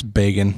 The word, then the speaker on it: Begin: The 1978 Camp David Accords were signed by Jimmy Carter, Israeli Prime Minister Menachem Begin, B-E-G-I-N. – I think it's Begin. – Begin. Mm-hmm. I didn't practice my pronunciations beforehand. And also were signed by Anwar Sadat, S-A-D-A-T, who was president of what country? – I think I can lock in.